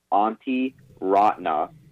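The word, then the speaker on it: Antti Raanta: And this one's going to be a hard one for you to say, I think, Cliff, so I'm going to tell you how to say it as best I can. It's Antti Raatna. There's a lot of vowels.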